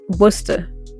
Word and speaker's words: Worcester: We say it Worcester.